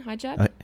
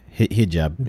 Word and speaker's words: hijab: Why do women wear hijab? Is that the correct pronunciation? Hijab? – Hijab, yeah. – Hijab, yep.